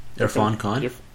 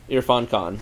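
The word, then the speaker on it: Irrfan Khan: how do you pronounce his name? Again? – Irfan Khan. – Irfan Khan, yeah.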